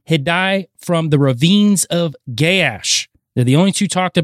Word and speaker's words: Gaash: We've got Hadai of the ravines of Gaash. This is spelled G-A-A-S-H. It is pronounced Gaash. However, I have seen it pronounced Gaash as well. So however you like to pronounce that, you can.